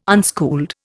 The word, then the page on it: unschooled: Indian